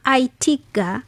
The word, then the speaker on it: AITIGA: AITIGA, kwa ufupi inatamkwa kama ITIGA, kuboresha ushirikiano wa biashara kati ya ASEAN na India.